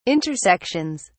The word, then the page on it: intersections: British English